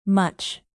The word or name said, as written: much